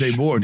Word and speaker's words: Debord: It's Debord. Debord. That's a French last name.